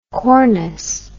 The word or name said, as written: cornus